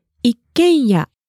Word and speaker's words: 一軒家: That's an 一軒家.